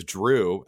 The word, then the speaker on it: Jrue: I do have some things cooking for Jrue. He has a name that lends itself to puns, I would say. Even his first name as well. Because Jrue, as I've learned myself, rhymes with pretty much everything.